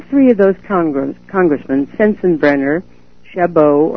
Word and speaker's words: Chabot: His name is Congressman Steve Chabot, C-H-A-B-O-T. I'm sort of giving it the French pronunciation. I'm not sure that I'm pronouncing it correctly. It may be Shabbat, but I sort of call it Chabot.